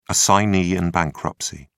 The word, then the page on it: assignee in bankruptcy: British English